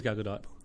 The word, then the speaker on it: Gal Gadot: It is Gal Gadot. The T is pronounced.